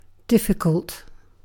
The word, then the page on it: difficult: British English